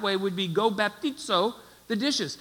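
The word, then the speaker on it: baptizo (βαπτίζω): But the English letters would look like this, and it would be pronounced baptizo.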